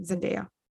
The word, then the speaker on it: Zendaya: Zendaya.